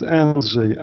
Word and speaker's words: Andrzej: This is in English is Andrew, and in Polish is Andrzej.